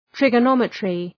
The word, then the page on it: trigonometry: ,trıgə’nɒmətrı